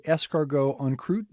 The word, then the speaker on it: escargot en croûte: Our faves include the escargot en croûte. Did I say that right? – That's correct.